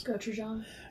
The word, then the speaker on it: Gochujang: Gochujang. – Gochujang.